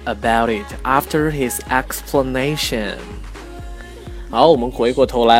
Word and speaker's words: explanation: 那么大家注意一下，它的动词跟名词的这个发音还是有区别的。动词呢是 explain，而名词呢是 explanation。那么在开头的那个 e 的发音是不一样的，explanation 和 explain。